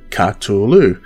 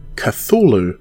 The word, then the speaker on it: Cthulhu: And more, more importantly, perhaps, how do you pronounce Cthulhu? Clearly, I pronounce it Cthulhu, but many prefer to enunciate the TH, saying Cthulhu. Lovecraft himself, however, suggested the word was pronounced very differently, potentially as Kalulu but I guess the truth is buried as we definitely can't ask him to say it again now for the record.